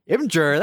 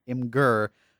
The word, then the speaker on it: Imgur: I'm pretty sure that the staff there made some posts once to address that question, so it's not Imgur, but it's supposed to be pronounced like Imgur.